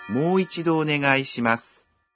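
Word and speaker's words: Мойчидо онегайшимас (もう一度お願いします): В первом слоге в слове «мо» гласное «о» долгое. Давайте потренируем произношение. – «Мойчидо онегайшимас».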